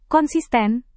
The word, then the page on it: konsisten: Indonesian